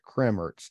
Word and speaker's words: Kremmerz: And I know I'm probably slaughtering the name. Uh, and you even told me how to pronounce it Kremmerz.